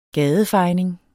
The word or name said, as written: gadefejning